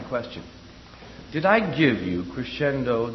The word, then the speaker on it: crescendo: That's pronounced in Italian, crescendo. So we say crescendo. And it's abbreviated music, C-R-E-S-C, period.